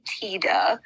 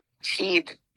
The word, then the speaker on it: Tiede: Okay, so his name is Bernie Tida. I had to Google how to pronounce that because it's spelled T-I-E-D-E. – Oh, I definitely would have pronounced that wrong. – Called him Teed or something.